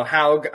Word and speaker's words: Haug: originally, so Haug.